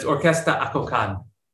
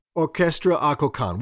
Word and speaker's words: Akokán: I feel like whatever in your is in your head is correct, but it's Orquesta Akokán. – Orquesta Akokán, which is how I was pronouncing it in my head.